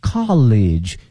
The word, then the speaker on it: college: 大学呢是 college， C O L L E G E，而这个呢是 C O L L E A G U E，发音不一样哈。